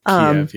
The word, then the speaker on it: Kyiv: He pronounced it the more Ukrainian way. I think Ken confirmed with the more familiar but also more Russian pronunciation. – Kyiv, yeah.